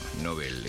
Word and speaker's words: Nobel: Nobel, eso es con B corta y es una palabra aguda que como termina en L no lleva tilde.